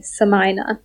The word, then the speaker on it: Somuayina: So Samina is spelled S-O-M-U-A-Y-I-N-A, Samina.